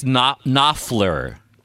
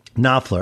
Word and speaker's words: Knopfler: Do you not pronounce the P? – Yeah, I believe it's Knopfler. – Knopfler from yes.